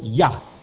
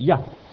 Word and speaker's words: Yah: You'll see also the word I-A-H. We would pronounce it Yah. Heard of Yahweh and that kind of thing? Yah.